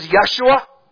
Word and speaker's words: Yeshua: Another way we can pronounce Joshua is Yeshua. Another way you can pronounce Jesus is Yeshua.